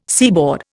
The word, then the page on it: seaboard: Indian